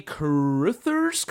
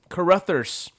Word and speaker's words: Carruthers: So, the film is loosely based on Lowell Cunningham's and Sandy Carruthers' comic book, The Men in Black. I'm pretty sure I butchered that person's last name. – Carruthers. – Anyways, Carruthers. Producers Walter F.